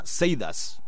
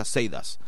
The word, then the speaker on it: Sadus: Bueno, por cierto, que se pronuncia Seidas, ¿vale? Se lo escuché a alguien que parecía que sabía de lo que hablaba, que se pronuncia Seidas.